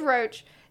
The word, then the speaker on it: Roche: Yorveth, Yeah, it is Roach. The guy's name is Roach too, but it's spelled different. R-O-C-H-E.